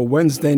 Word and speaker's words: Wednesday: The 76ers are in town on Sunday, and the Bulls drive up I-94 for a Wednesday night game. And remember, when spelling Wednesday, the D is silent, just like the reaction to my jokes.